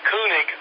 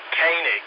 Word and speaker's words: Koenig: That was Walter, it looked like Koenig, but he pronounced it Koenig, that played Chekhov.